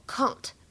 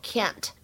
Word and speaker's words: can't: 在这段台词朗读过程当中呢，我们需要注意一下，首先开头的位置，这个 can't 它的英美发音不同，在英式英语当中呢，它读作 can't，而在美式英语当中呢，读作 can't。